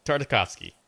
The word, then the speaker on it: Tartakovsky: I'd never clinged on to it as hard as I did with, like, the Clone Wars and especially Gendy Tartakovsky. Oh, man, I'll never say his name right. Okay. You can say it for me. – Tartakovsky.